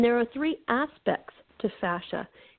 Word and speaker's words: fascia: or the more sciencey word for that is fascia, like fashion, but it's spelled F as in Frank, A-S-C-I-A, fascia. Not to be confused with fascia or facial.